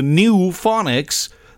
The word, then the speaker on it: Nufonics: All the better because the band is the New Phonics. That's spelled N-U-F-O-N-I-C-S.